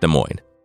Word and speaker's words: Des Moines: Just kidding, I know it's pronounced Des Moines.